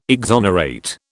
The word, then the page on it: exonerate: British English